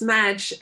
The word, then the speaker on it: MADGE: So please tell us, for the listeners, who are the people behind MADGE, and I don't know if MADGE is the right word, right way to pronounce it. – Yes, it is. It's MADGE,